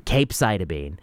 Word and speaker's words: capecitabine: And then comes along bill cap, capecitabine versus observation. I guess listeners want to hear the hard K sound on that, and so I'm going to give it to you. Your capecitabine.